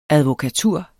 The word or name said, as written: advokatur